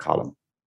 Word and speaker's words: Colm: Yeah, there's like three ways of pronouncing it in Ireland alone. So I always say it's like you're reading a newspaper Colm.